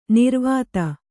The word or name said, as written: ನಿರ್ವಾತ